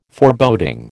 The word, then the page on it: Foreboding: American English